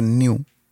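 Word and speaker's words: Nu: Pero bueno, que le iban a poner new, que una letra es N-U. – Uh-huh. – Se pronuncia new.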